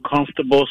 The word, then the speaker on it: comfortable: I mean, if you pronounced every syllable, it would be comfortable. – Yeah, but if you don't pronounce every syllable, it's comfortable. But how are you feeling?